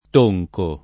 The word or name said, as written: Tonco